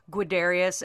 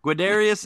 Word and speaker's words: Guiderius: But the most frequently cited examples is like the prominence of homoeroticism throughout the play with Gerdius and how do you say their names? – Guiderius and Arviragus. – Them. Guiderius and Arviragus.